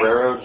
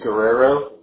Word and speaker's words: Guerrero: Guerrero, G-U-E. It's Guerrero, G-U-E-R-R-E-R-O.